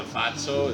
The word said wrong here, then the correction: Fausto: Is that how you pronounce that? – That's the Fausto.